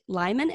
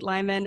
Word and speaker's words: Lyman: Today, Alyssa and I are joined by Kate Lyman.